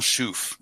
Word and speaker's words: Chouffe: Houblon Chouf. That is actually from Belgium. So the La Chouf beers, Chouf breweries, Chouffe is how it's spelled.